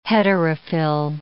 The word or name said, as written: heterophil